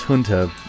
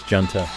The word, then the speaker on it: Junta: Not pronounced Junta, spelled like Junta, but it's Junta.